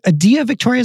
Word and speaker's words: Adia Victoria: We also have, is it Adia Victoria? Is that how you say it? – Yeah, she's like a gothic kind of blues artist.